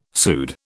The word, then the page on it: pseud: British English